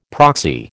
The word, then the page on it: proxy: American English